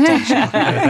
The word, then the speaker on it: Taoiseach: It's really like the shock you get from a good cup of tea. It's just Taoiseach.